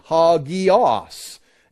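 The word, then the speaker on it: hagios (ἅγιος): Here's how you pronounce it, hagios.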